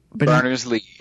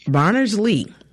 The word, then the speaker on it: Berners-Lee: Berners-Lee. – Berners-Lee.